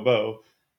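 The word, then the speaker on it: Bou: Is it Bo or Bow, by the way? – I thought it was Gustavo Bo. Am I saying it wrong? – I'll go with Bo.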